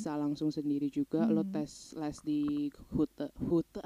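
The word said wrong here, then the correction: Goethe: aduh kok dapet pronunciation gue bahasa Belanda. Di Gute. Di Gute.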